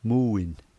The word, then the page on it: muin: slow